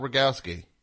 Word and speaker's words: Rogowski: Scott Rogowski.